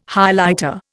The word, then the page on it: Highlighter: Indian